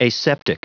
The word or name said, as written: aseptic